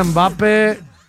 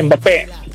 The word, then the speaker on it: Mbappé: Mbappé, porque tiene acento en la E. – Mbappé.